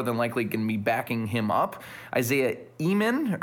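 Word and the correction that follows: Ihnen: or Enan, excuse me, I pronounce that.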